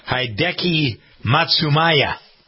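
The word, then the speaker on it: Hideki Matsuyama: Hideki Matsumaya.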